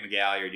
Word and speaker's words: McGalliard: My name is Grant McGalliard. You can find me on Twitter, spelled like it sounds, @GrantMcGalliard.